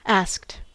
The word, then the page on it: asked: American English